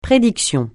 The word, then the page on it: prédiction: francais